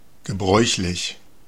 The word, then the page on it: usual: /ˈjuːʒuəl/